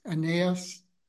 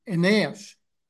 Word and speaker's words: Aeneas: So here I chose to pronounce the name like the A-E-N as like pain, as in pain, ain, and then naos, Aeneas. I'm not really sure if that's an accurate pronunciation, but anyway, beginning with chapter 9, verse 32.